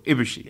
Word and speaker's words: Ibushi: Yeah, stressing the first syllable, Ibushi. Yeah.